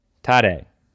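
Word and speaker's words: Tadej: Tim, if you can never remember how to say his name, it's today. Tade.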